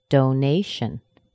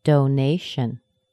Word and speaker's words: donation: Donation. Donation. Nay is the primary stress.